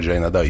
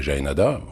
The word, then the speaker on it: Jaenada: ils ont décidé de le prononcer à la française, comme on dirait Laetitia, par exemple, et non pas Laetitia. Et donc voilà, et mon père, depuis qu'il est petit, sachant, parce qu'il parlait très bien espagnol, il était prof d'espagnol, sachant très bien qu'on disait Jaenada, voire Jaenada, mon père a prononcé toute sa vie, jusqu'à donc 40 ans, ou 38, 40 ans, Jaenada.